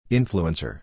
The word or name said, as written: Influencer